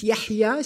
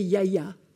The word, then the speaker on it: Yahya: Si vous dites Yahya, ça fait Yaya.